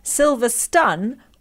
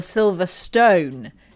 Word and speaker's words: Silverstone: Silver Stun or Silver Stone? Scene or Sin? The latter is a Palmer pronunciation and it gets on my tits.